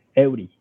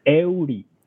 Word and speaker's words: Eury: Eury. – Say it again for me. – Eury.